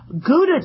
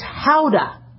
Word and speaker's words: Gouda: That's not how you pronounce Gouda. It's Gouda. That's apparently from the Netherlands. It's named after the city in the Netherlands, and they pronounce it with an H. So rather than saying howdy, it's Gouda for Gouda cheese.